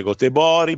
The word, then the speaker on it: Göteborg: Ragazzi, è stato il Bruges per 50 anni, per noi è il Bruges, dai, su, mamma mia, E allora Göteborg, devi dire Gotebori, Borg era Bori, mamma mia, che...